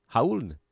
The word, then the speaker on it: Raul: Raul Neto, and I know it's an R, but I've watched enough Utah Jazz games over the years to know that the R in his name is actually pronounced like an H. Raul, or silent.